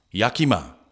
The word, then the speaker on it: Yakima: Yamamaki Monster. I'm sure I'm pronouncing that incorrectly. – Yamamaki. Yakima.